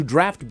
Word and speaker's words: draught: D-R-A-U-G-H-T. – Yeah. Why is that hard to say? – Drought? Actually, no, it's draft. – Draft. So there's a silent F. – D-R-A-U-G-H-T. Draft.